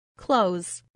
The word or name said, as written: close